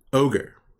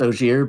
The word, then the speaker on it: Ogier: I read one of them as ogre. Is that, I don't want to ask. – It's Ogier, but right.